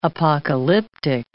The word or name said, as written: apocalyptic